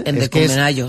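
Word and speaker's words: Ndikumwenayo: Y en atletismo, ayer el atleta del Playas de Castellón, nacido en Burundi pero nacionalizado español, Thierry Endicumenayo, lo he dicho bien. – Sí, Endicumenayo, sí.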